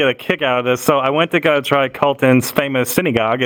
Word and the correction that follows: sinigang: Am I pronouncing it correctly? – Sinigang.